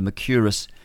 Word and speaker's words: Mercuris: And Alexander, if you want some real detail, Alexander Mercuris, that's spelled M-E-R-C-O-U, I think it is, R-I-S, Mercuris, like Mercury.